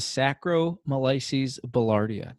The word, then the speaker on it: Saccharomyces boulardii: Saccharomyces boulardii.